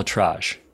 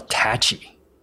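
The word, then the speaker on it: ElAttrache: His name is pronounced Dr. Neil L. Attrage, not Neil L. Attachi.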